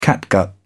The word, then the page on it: catgut: British English